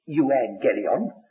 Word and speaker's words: euangelion: If you're reading the Greek itself, you'll see the letter U comes, but we generally rewrite the word for our modern pronunciation. We don't say we're going to preach the euangelion, that we say we preach the evangel.